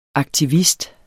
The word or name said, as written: AKTIVISTER